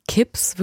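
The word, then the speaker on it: K!PZ: Notte die Sicilia, Nacht auf Sizilien von einem Künstler namens Kips, würde ich immer aussprechen, also K, Ausrufezeichen PZ, erschienen auf der Italo Disco Compilation Volume 1 bei Italo Hub.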